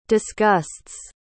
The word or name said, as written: disgusts